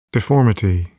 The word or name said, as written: deformity